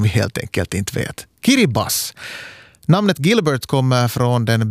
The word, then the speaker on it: Kiribati: Där T-I i slutet av då, Kiribati, då man läser det sådär ordagrant, uttalas som S. Kiribas alltså.